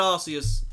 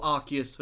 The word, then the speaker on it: Arceus: We've had Arceus, or Arceus,